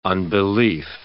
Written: unbelief

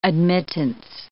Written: admittance

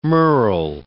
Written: merle

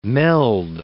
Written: meld

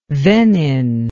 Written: venin

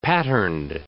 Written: patterned

